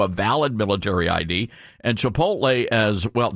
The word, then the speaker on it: Chipotle: My mom calls it Chipotle. – Oh, Chipotle.